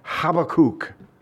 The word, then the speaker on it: Habakkuk: And this monk told him to read the book of Habakkuk. Okay, that's how you say it.